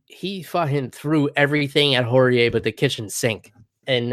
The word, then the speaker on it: Horie: Hakeem Dawodu, nasty head kick knockout over Yoshinori Horie. I would have swore it was Hori, because that sounds like a more Japanese pronunciation, but Horie, Horie.